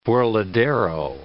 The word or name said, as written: burladero